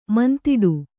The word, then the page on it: ಮುಂದಿಡು: kannada